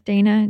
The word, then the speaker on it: Dana: Instagram under my name again, like Dana James. Easy. – Yes, I know. So for everybody listening, her name is Donna, but it's spelled like Dana.